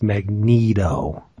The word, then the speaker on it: Magneto: You pronounce it the way it should be pronounced, Magneto.